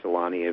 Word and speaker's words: Selänne: as did Teemu Selänne when he was in Winnipeg. And that got changed after his first year because so many people were saying Solani eventually agreed to it.